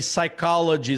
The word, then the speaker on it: psychology: O P, ou, é, ou então segui, uh, seguido de S também, psychology, psychiatrist. O P é mudo.